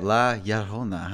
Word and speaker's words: La Llorona: and I saw Curse of La Llorona. – Oof. That's how you say it, La Llorona, huh? – Yeah.